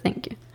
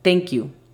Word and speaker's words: thank you: Aquí es muy importante tener presente la pronunciación correcta de las letras TH de la que hablamos al comienzo del episodio, porque no quieres decir thank you, quieres decir thank you. Recuerda, coloca tu lengua entre tus dientes y soplas, así de fácil y sencillo.